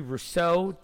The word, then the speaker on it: Rousseau: Jets at five go Gregory Rousseau, defensive end. Did I pronounce that last name right? – Yeah, Rousseau.